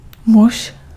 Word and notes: muž: [muʒ]; /muʃ/

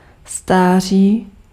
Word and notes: age: American English; /eɪdʒ/

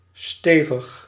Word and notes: solid: /ˈsɑlɪd/; /ˈsɒlɪd/

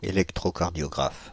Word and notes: électrocardiographe: [e.lɛk.tʁɔ.kaʁ.djɔ.ɡʁaf]

